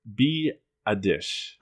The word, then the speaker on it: Biadasz: I am looking at, in the 4th round, the Eagles taking Wisconsin center Tyler Biadasz. – I like that you pronounced that correctly. – Yes, phonetically. I looked it up.